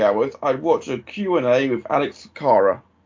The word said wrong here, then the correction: Cora: With who? Oh, Alex Cora. Okay, geez. Well, once you pronounce it right, I've got some idea.